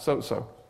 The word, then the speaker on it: sozo: The Greek word is S-O-Z-O. How do you pronounce that? So-so. So-so. That'll do. But there's a little preposition in front of it. D-I-A.